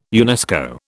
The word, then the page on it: UNESCO: British English